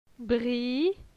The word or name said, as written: Bri